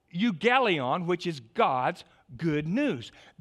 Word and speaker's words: euangelion: It's pronounced euangelion.